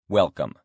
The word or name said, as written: welcome